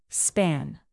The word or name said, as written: span